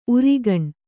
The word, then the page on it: ಊರಗುಣಿ: ūraguṇi